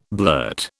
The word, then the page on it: blurt: British English